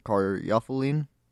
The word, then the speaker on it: caryophyllene: terpene, caryophyllene, and pronounceline.